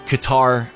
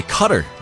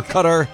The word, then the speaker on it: Qatar: And we're getting conflicting because, look, I'm going to watch tonight with anticipation to listen to the announcers because I'm telling you, I used to say Qatar like a lot of people say, like rhymes with guitar. – Yeah. – Spelled with a Q, but it's pronounced Qatar. And then I started hearing more people say Cutter, like it's pronounced like Cutter.